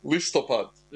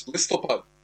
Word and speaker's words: Listopad: Listopad. – Listopad. – That's right. So it's Listopad.